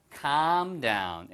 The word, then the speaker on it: calm down: 발음만 길게. come이 아니에요. come down은 내려와라는 뜻이니까. hey, come on. come down here. 이렇게 쓰이니까. 이거는 calm down. L이 묵음이고요.